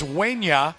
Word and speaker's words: Wanya: But according to Mark Raimondi, and by the way, he gave us a pronunciation, it's Wanya. – Wanya. – Not Wanya, but Wanya.